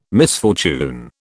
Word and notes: misfortune: British English

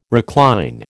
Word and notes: recline: American English

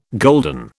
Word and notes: golden: British English

